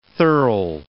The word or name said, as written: thirl